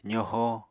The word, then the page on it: zoho: apatani